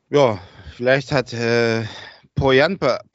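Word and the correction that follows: Pohjanpalo: Pochjanpalo, so wird er ausgesprochen, seine, äh, seine Kontakte spielen lassen.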